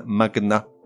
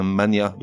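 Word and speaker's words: magnat: Or, contre toute attente, certains mots se prononcent avec le G séparé du N, comme un magna, et non pas un mania de la presse par exemple, ou encore pugnace, c'est-à-dire quelqu'un de combatif, et non pas pugnace.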